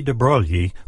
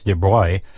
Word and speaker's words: de Broglie: He was Louis de Broglie, whose name the French pronounce more like de Broglie.